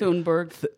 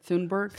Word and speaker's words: Thunberg: It's your, Thunberg. – Th- th- how do you say it? – Thunberg.